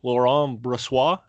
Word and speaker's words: Laurent Brossoit: Laurent Brossoit, am I saying that right, Ross? Yeah. Got the thumbs up over the webcam, so I'm saying that correctly.